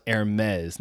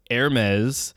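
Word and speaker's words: Hermès: So for those that that know of Hermes, it is pronounced Hermes, not Hermes. There's no huh there. It's a air with the ER.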